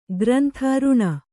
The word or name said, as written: ಋಣ